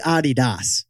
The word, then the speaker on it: Adidas: How's it really pronounced? – It's really Adidas, named after Adi Dassler.